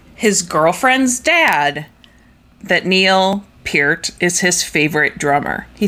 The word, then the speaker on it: Peart: You're telling me it's Peart? – I think that's actually how you say it.